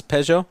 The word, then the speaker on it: Peugeot: That's a Peugeot. – I thought it was Peugeot, but it... – Or I don't know. That's what we used to say in Czech. – It's Peugeot.